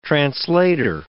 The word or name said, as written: translator